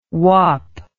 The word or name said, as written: whap